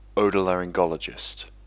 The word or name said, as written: Otolaryngologist